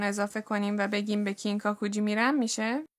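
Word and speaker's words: 金閣寺: اگر بخواید بگید که دوست دارم به معبد مشهور کینکاکوجی در شهر کیوتو برم چه میگید؟ تلفظ این معبد هست کینکاکوجی.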